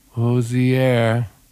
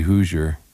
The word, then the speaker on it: Hozier: We listened to Too Sweet by Hozier. I always get grief because I say Hoosier.